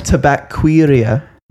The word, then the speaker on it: Tabaquería: It's 1844 Tabaculara, 1884. – Tabaquera. – Yeah, I never know how to pronounce Spanish words. – Tabaquería.